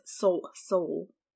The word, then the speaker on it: Seoul: how do you say that? Seoul? The Korean city? – Seoul? – Is that what it is? – Seoul? – Yeah, Seoul.